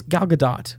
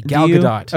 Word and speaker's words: Gal Gadot: I think it's Gal Gadot. – Is it Gadot? – I'm pretty sure you pronounce the T. Gal Gadot.